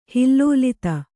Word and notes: ಹಿಲ್ಲೋಲಿತ: kannada

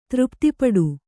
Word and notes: ತೃಪತಿಪಡು: třpati paḍu

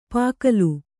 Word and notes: ಪಾಗಲು: pāgalu

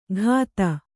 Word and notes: ಘಾತ: ghāta

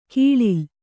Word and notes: ಗಳಲೆ: Kannada

